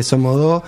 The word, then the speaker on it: Somos Dos: Y además en Bomba Estéreo tiene algo súper interesante, que vos escuchás el primer disco y escuchás es el último, este Somodó, porque aparte se escribe Somos Dos, pero la, dice Somodó, ¿no? La letra, cuando la cantan.